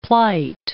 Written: plight

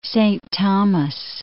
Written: Saint Thomas

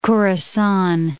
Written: Khurasan

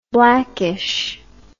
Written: blackishness

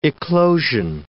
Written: eclosion

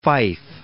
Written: Phyfe

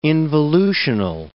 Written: involutional

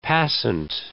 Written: passant

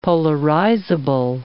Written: polarizable

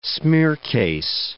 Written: smiercase

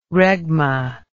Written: regma

